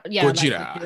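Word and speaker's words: Gojira (ゴジラ): The kind of like anglicization of it has turned more into Godzilla, but it is actually supposed to be pronounced more like how the Gojira. Japanese people Gojira.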